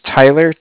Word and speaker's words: Tyler: Okay, my name is Tyler, T-Y-L-E-R. – Mm-hmm. – And I'm calling with Cardiff. Like I said, it's spelled C-A-R.